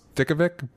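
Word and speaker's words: Djokovic: From Eva Dojovic. I think I did a pretty good job with that last name. Dojovic? Or Jovic? – I think it's pronounced Dickovic, but that's not your fault. – D-J-O-K-O-V...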